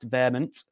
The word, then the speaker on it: VHEMT: Yeah, my first guest at 7 is Les Knight, no relation, who is a volunteer in the Voluntary Human Extinction Movement, or VHEMT. Pronounced Vehement for short.